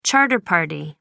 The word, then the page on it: charter party: American English